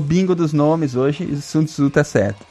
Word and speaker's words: Sun Tzu: É assim que fala mesmo? Sun Tzu? – É a primeira pronúncia certa da noite? Não, tá certo, Sun Tzu.